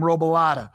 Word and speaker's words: Robolata: There are very few things that rhyme with my name, Robolata. All right. And so, whenever something rhymes with my name, I immediately love it a little bit more. And so, that is why burrata is one of my favorite cheeses of all times.